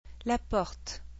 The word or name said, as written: la porte